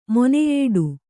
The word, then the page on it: ಮೊನೆಯೇಡು: moneyēḍu